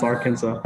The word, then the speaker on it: Arkansas: Man, I'll never ever understand why Kansas is pronounced Kansas and Arkansas is pronounced Arkansas.